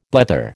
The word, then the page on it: blether: American English